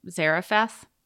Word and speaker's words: Zarephath: Then the word of the Lord came to him, arise and go to Zarephath. How do you say that? – That was close enough.